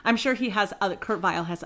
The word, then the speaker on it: Vile: And Kurt Vile is definitely pronounced with a V sound here for the American audience.